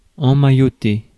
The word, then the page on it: emmailloter: France; [ɑ̃.ma.jɔ.te]